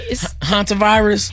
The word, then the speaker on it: Hantavirus: What's the pronunciation? – You said it right. – Hantavirus? – Hanta.